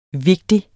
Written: vigtig

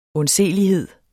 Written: undselighed

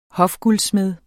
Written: hofguldsmed

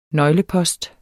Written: nøglepost

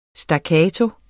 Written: staccato

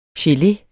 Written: gele

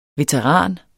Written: veteran

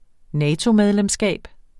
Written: NATO-medlemskab

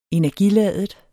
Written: energiladet

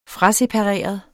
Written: frasepareret

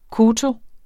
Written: koto

